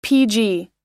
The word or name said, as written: pg